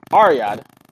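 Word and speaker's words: Riyadh: I said it wrong again. Let's try it again. For the pronunciation, shall we? Ariyad event as scheduled.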